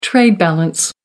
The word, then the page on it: trade balance: American English